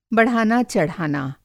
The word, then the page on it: बढ़ाना-चढ़ाना: بڑھانا چڑھانا